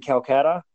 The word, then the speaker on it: Calcutta: I know, if I remember right, you were born in Calcutta.